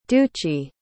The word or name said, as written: duci